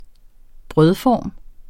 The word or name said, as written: brødform